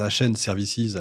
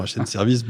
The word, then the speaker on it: HN Services: Alors c'est vrai que je le prononce, je le prononce HN Services à l'anglaise, mais évidemment HN Services, bien sûr.